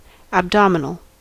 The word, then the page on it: abdominal: /æbˈdɒm.ə.nl̩/; /əbˈdɒm.ə.nl̩/; /æbˈdɑm.ə.nl̩/; /əbˈdɑm.ə.nl̩/